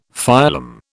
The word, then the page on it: phylum: British English